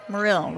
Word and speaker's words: Morrill: Morrill, right? – It's spelled Morrill phonetically. – It is. It phonetically is.